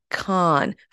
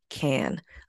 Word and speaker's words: Cannes: It's actually Cannes, like tomato can. That's how the French people pronounce it. That's how industry insiders pronounce it. So do not pronounce it Cannes Film Festival.